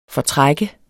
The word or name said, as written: FORTRAK